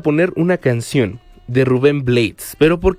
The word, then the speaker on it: Blades: por lo tanto la pronunciación correcta sigue la fonética inglesa, sonando como blades, parecido a la palabra blades, que significa cuchillas, navajas, hojas en inglés.